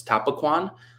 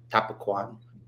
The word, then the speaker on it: Tapaquon: So you're going to see that my last name is it's Tapaquon, but you see how it's spelled at the end, Q-U-O-N. That's because of like the French influence on our Cree language.